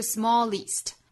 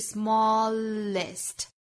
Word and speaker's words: smallest: 是 smallest 这个词后面的 est 读 ist 大家不要把它读成了 east 不是 smallest 而是 smallest list